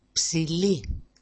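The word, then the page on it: psili: Greek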